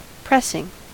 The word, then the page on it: pressing: American English